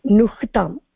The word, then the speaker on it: ह्नुताम्: इत्युक्ते वस्तुतः हकारस्य उच्चारणं नकारस्य अनन्तरं अनन्तरम् इति वा ओके ओके नुह्ताम् ओके